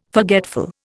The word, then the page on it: forgetful: Indian